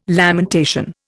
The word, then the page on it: lamentation: Indian